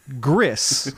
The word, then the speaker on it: Gris: It's spelled G-R-I-S, but it's Spanish, so it's actually pronounced Gris, which is gray.